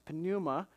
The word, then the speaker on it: pneuma: It's one of those common Greek words, pneuma, or some people say pneuma, but you pronounce the P sound anyway.